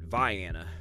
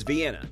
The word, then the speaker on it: Vienna: there is a town that is spelled like that, but the locals have always pronounced it Vienna, and I always have to be very careful because I know the correct pronunciation, certainly for the city in Austria, is Vienna.